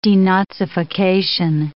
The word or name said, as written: denazification